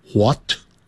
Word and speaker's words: what: W-H-A-T 这个是在英式的念法会念成 What 可是在美式念成 What 啊，都可以那个 H 的音就没有了